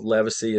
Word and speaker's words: Livesay: And, and you know, most people go, the Livesays or the Livesays. And I say, it doesn't matter.